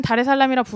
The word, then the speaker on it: 다르에스살람: 다르에스살람. – 네. 그렇게 쓰지만 사실은 다레살람이라 부르고 발음하고 읽기에는? – 현지에서는 그냥 다라고 해요.